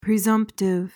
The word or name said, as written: presumptive